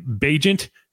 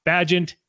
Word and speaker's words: Bagent: Week 9, Saints, Bears, you're going to be looking at Tyson Bagent, not Bagent. It is Bagent.